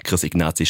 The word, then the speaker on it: Chris Ignazi: Ja, mit Chris Ignazi, wie es Aaron gerade schon richtig gesagt hat.